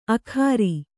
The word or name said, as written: ಆಖರಿ